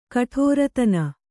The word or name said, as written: ಕಠೋರತನ